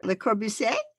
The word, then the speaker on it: Le Corbusier: Le Corbusier? – Yeah, that's right.